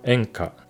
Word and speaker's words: 怨歌: 其实怨歌呢，日文发音就叫 enka，其实跟演歌的发音是一样的。